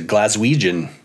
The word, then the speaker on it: Glaswegian: We were actually saying recently how Glasgow has one of the great denonyms, a Glaswegian.